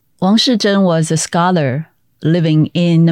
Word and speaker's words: Wang Shizhen: so, the National Palace Museum, or NPM, I guess, has recently opened up a new exhibit on Wang Shizhen. Am I saying that correctly? – Yes, very okay. correct.